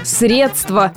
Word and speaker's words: средства: А во всех словарях – средства, средства и посредством, исключительно с ударением на первый слог, является верным вариантом.